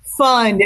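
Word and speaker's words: Pfund: It's the guy's name is Kenny Fund. It's P-F-U-N-D. It's a German name. You don't pronounce the P, just the F-U-N-D.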